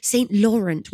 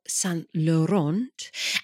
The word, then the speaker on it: Laurent: So we will be talking about Zweigelt, Blaufränkisch, St. Laurent, which actually is how you're supposed to pronounce the grape variety, not St. Laurent.